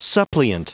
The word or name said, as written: suppliant